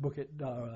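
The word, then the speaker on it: Bukit Darah: We call it Bukit Dara. – Now how do you spell that? – B-U-K-I-T, which is Hill, Bukit, Dara, D-A-R-A-H, Blood.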